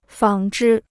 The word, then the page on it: 纺织: fǎng zhī